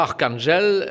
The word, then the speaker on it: Arkhangelsk: Non, et là, Arkhangelsk, si je prononce bien, ben, ils arrivent avec des températures de moins 20 degrés. – Vous parlez magnifiquement russe, hein, dis-donc.